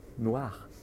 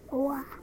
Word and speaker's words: noir: Noir. – Noir.